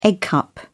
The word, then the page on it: egg cup: British English